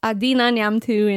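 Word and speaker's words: Adina Neamtu: It's Adina Nyamtu in one word.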